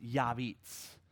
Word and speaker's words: Jabez: Or Jabez, the one who causes pain. In fact, he simply was a pain. In fact, his name in his language would have been pronounced Yabitz.